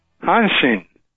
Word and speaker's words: Hanshin: How do you say, Hashan Tiger, George Altman. How you doing, Mr. Altman? – I'm doing fine, thank you. – How do you pronounce that team from Japan? – Hanshin.